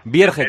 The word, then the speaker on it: Vierge: Vierge. – Vierge, como se escribe, ¿no? – Sí, es un apellido de origen francés, pero él es de Barcelona y se pronuncia tal cual lo lees.